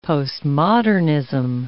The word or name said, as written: postmodernism